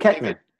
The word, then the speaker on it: Koechner: I really liked listening to you talk about, I can't remember how to pronounce his last name. Is it Kochner or Kochner? – Oh, David Koechner.